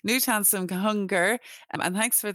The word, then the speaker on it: Knut Hamsun: I used to go around calling him Canute when I first discovered him, um, but I'm delighted that you've you've you've corrected me there, so I now know in future Knut Hamsun.